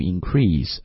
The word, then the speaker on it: increase: 就 unanimously for the increase，在这里注意这个词的读音不读 increase 啊，因为读 increase 的时候是重音在第二个音节是动词啊，在这里是个名词，所以不读 the increase。